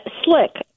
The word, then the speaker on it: Slick: Slick, like oil slick.